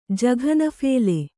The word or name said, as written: ಜಘನಫೇಲೆ